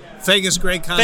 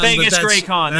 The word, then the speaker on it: Fagus-GreCon: Did I say it right? – Fagus-GreCon. – Fagus-GreCon. There we go.